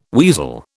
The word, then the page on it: weasel: British English